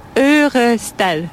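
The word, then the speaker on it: Ørestad: Na początek może ustalmy, jak prawidłowo wymawia się nazwę tej dzielnicy. – Örestel. – Örestel. – Pretty close.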